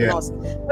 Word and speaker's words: NOSC: Okay, so if people can take out the K, the idea of K, I mean, knowledge, you don't pronounce the K, I just focus on NOSC.